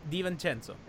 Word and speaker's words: DiVincenzo: The conversation has to be how Spoelstra made Gordon Hayward into Dante DiVincenzo. – Did you say that how, right? Was that the right pronunciation? – DiVincenzo.